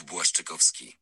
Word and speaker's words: Błaszczykowski: My winger is Jakub Błaszczykowski.